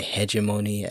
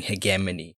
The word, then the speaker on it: hegemony: I know there'll also be some people that say hegemony, and there's nothing wrong with pronouncing it like that, but I'm going to be saying hegemony.